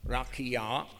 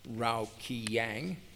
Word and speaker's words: Rakia: The explanation to facsimile 2, figure 12 reads, and I'm going to pronounce this correctly, Rakia, not Rao Kiyang.